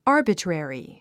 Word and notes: arbitrary: ɑ’ː r bətrèri; アァビトゥレリィ

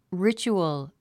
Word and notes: ritual: rítʃuəl; リチュヲォ

